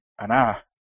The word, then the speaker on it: Anah: A-N-A-H. – So Nadir just yells out, ahan! – Wait, spell it again? – A-N-A-H.